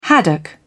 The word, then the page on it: haddock: British English